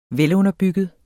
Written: velunderbygget

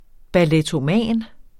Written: ballettoman

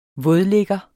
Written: vådligger

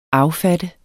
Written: affatte

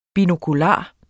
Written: binokular

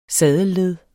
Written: saddelled

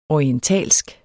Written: orientalsk

